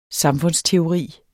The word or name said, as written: samfundsteori